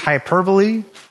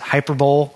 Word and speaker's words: hyperbole: Saying, well, is that just hyperbole? Or as I used to pronounce it, hyperbole.